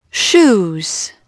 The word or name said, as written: shoes